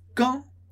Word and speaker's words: quand: E aqui muito legal a pronúncia de quand. Quand a gente pronuncia sem o D, esse som nasal do an. An. Som que sai de uma só vez. An. An. Quand.